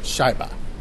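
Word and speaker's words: Shaiba (шайба): So that means almost every seat is filled with people shouting, Shaiba, which means puck. Did I say that right? – Shaiba.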